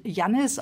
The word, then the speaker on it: Janis: Janis Joplin wird Yanis auf Spanisch ausgesprochen.